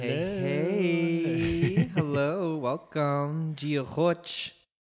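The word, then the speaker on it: Dia dhuit: And it's weird because it's spelled like not like Giochoch, but that's how you say it.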